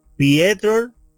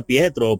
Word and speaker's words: Piotr: Al, eh, eh, eh, yo, yo, yo entiendo que se pronuncia Pietro, Piet, se escribe Piotr.